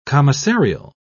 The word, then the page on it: commissarial: British English